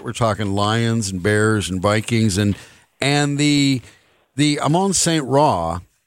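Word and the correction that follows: Amon-Ra St. Brown: and Amon Ra St. Brown. I'm sorry, I got too many syllables there and I can't get them right.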